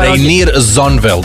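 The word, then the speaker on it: Reinier Zonneveld: Reinier, kako si izgovara? – Reinier Zonvel.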